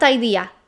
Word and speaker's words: idea: So it's pronounced like in the word see, but just a little bit shorter. Let's practice it. Idea, not idea, idea.